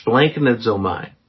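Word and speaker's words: splanknezomai (σπλαγχνίζομαι): The Greek word is splanknezomai. Yes, splanknezomai. That first syllable, splank, has six consonants and one vowel.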